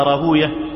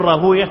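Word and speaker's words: راهويه: وقال حاشد ابن إسماعيل رأيت إسحاق بن راهوية جالسا على المنبر، وطبعا احنا بنقول راهوية ما بنقولش راهوية، بنقول سيبوية مش سيبوية، لأن هناك نطقان صحيحان، المحدثون ينطقون كل اسم ختم بويه كما أنطقها كذا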